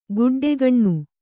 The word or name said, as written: ಗುಡ್ಡೆಗಣ್ಣು